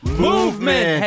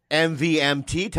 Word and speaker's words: MVMT: Movement. – Hey, what time is it? – MVMT time. – MVMT baby. – Time, movement. Baby. It's pronounced movement.